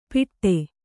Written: ಪಿಟ್ಟೆ

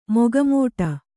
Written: ಮೊಗಮೋಟ

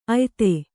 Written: ಆಯತಿ

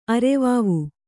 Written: ಅರವಾವು

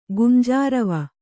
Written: ಗುಂಜಾರವ

